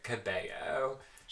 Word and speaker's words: Cabello: I mean, not that I'm like the gatekeeper, but I feel like she should like pronounce her last name like Cabello. – She does.